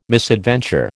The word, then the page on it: misadventure: American English